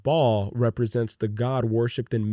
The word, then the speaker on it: Baal: And it's spelled B-A-A-L. And Siaga. – Mm-hmm. – So Baal. Yes, Baal.